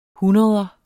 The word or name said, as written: hundreder